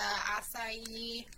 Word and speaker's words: acai: You get the acai.